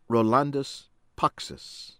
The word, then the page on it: Rolandas Paksas: American English